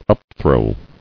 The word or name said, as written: Upthrow